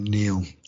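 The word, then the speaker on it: Niall: He pronounces his name Neil, who's a sort of British historian that appears on the television from time to time and is, not very controversial.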